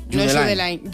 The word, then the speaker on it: Judeline: Pues entonces Judeline. Judeline. Si es de Barbate, Judeline.